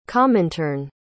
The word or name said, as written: comintern